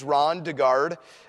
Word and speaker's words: Ron DeGarde: Well, for those of you that do not know me, my name is Ron DeGarde. And I have to say my name slowly. If I say it fast, it sounds like Rhonda Guard. And I'm not a woman preacher.